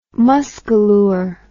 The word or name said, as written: muscalure